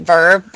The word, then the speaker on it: VRV: And I do know Crunchyroll feeds to a, it's VRV, I think you just pronounce it Verb, but that's what we have.